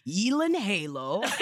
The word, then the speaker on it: Gyllenhaal: The correct pronunciation of the last name Gyllenhaal is actually Yelan Halo,